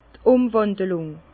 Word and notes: d' Umwàndelùng: Bas-Rhin; Herrlisheim

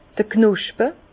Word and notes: d'r Knospe: Bas-Rhin; Herrlisheim

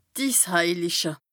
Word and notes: d' Issheiliche: Bas Rhin; Strasbourg

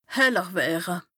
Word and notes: heller wëre: Bas-Rhin; Strasbourg

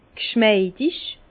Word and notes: g'schmeidig: Bas-Rhin; Herrlisheim

